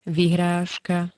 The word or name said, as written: vyhrazka